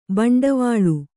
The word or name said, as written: ಭಿಂಡಿವಾಲ